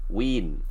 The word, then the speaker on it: Whedon: So, Whedon, there we go. – It's like long E.